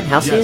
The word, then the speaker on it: Halcyon: Okay, am I pronouncing that right? Halcyon? – Yes, yes.